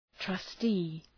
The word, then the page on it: trustee: trʌ’sti: